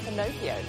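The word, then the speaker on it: finocchio: Its Italian name is Pinocchio, rhymes with Pinocchio.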